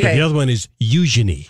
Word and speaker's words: Eugenie: I was just informed by one of our lovely listeners, and her husband is from London, that over in the UK, you know how you pronounce that princess's name? – Oh. – Eugenie. – You know, okay, Eugenie.